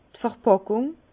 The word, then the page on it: d'r Verpàckung: Alsatian; Bas-Rhin; Herrlisheim